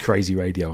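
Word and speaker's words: Kreisiraadio: This friend in 2008, an Estonian band called Crazy Radio, that sounds easy, but it's not spelled like that at all, had a song called Leto Svet. – It's K-R-E-I-S-I-R-A-A-D-I-O. So I assumed it was Crazy Radio. – I know, disappointing. It's just Crazy Radio, I'm afraid.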